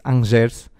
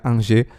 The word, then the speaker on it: Angers: dar aqui essa correção que eu no último podcast disse Angers, Pá, eu não sei falar francês, eu, eu, eu, disse logo e já tinha a certeza que o nome estava mal pronunciado. Sou bastante mal com nomes, mas não, mas agora já me corrigiram. Será que é que se pronuncia Angers? Uh, por isso agora já sei.